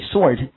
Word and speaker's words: sword: 记住这里 sword, 好，那么 w 是不发音的，所以记住 sword 这个词它表示剑的意思，可以了吗？